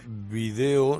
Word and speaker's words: video: Es que video no lleva acento en la I. – Y además es lo del inglés también que te... – Claro, es pensar en inglés. – Pensar en inglés. – Claro, también. – Es que eso que te... – Es el problema. – Pero no lleva tilde video. Por lo tanto, es video.